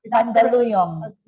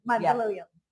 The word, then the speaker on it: Mandaluyong: How do you pronounce Mandaluyong? – Mandaluyong. – Mandaluyong. – Yeah, Mandaluyong City.